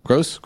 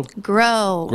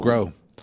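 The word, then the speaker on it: Gros: That was Renee Grow. Gross? Grow? – Grow. – Grow. – No S. – No S. – Well, silent S. – Silent S. Glad I'm Wrong is the brand new album,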